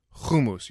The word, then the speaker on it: Hummus: Hummus. You start with who and then mousse, like chocolate mousse, which is kind of like the texture that we're going for when we actually make hummus.